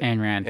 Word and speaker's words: Ayn Rand: Ayn Rand.